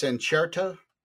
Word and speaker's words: Incerto: And the word that he used was, I'll probably butcher it, but it's incerto, I believe.